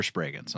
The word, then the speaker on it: Spragens: Could be Spragans. – Spell it. – Spragens. I mean, if you're in America, you're Spragans.